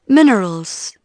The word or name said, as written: minerals